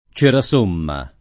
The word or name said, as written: Cerasomma